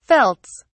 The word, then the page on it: felts: British English